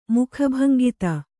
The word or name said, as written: ಮುಖಭಂಗಿತೆ